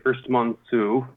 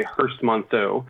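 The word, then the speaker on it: Herstmonceux: So the French would say Hurstmonceau. The British say Hurstmonceau.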